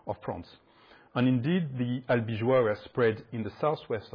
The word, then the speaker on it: Albigeois: So the Albigensians, difficult for me, in French it's Albigeois, easier.